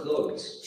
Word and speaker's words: clothes: Shop for clothes. – Shop for clothes. Olha que legal a pronúncia, né? Clothes. Tem um D, DZ no final.